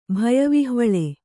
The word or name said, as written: ಭಯವಿಹ್ವಳೆ